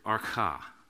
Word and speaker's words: arche: In verse 1, that word beginning, or whatever form of that word your translation uses, beginning, is in the Greek the word archa, archa, and it's spelled A-R-C-H-E, for those of you who write in your margins and take notes.